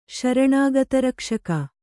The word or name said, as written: ಶರಣಾಗತರಕ್ಷಕ